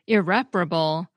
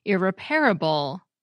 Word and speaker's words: irreparable: For example, irreparable seems to be changing from four syllables with a main stress on the second, irreparable, to 5 syllables with the main stress on the third, irreparable.